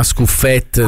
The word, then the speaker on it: Scuffet: Gli dici si chiama Scuffet, non è francese, Scuffet. Non si chiama Scuffet. – Scuffet, oh, è friulano.